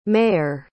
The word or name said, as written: mayer